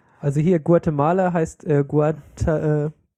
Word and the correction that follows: Guatemalteken: Nein, ich kann es nicht mehr aussprechen. Guatemalteken. – Ach so. – Malteken. – Malteken. – Ja, Guatemalteken.